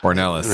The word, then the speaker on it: Ornelas: Ornelas. – Right on. I never say your last name. – That's a fine white pronunciation. Otherwise, we're trying to sound Mexican, and it's too much.